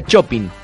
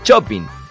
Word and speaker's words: shopping: Se dice shopping, como shampoo.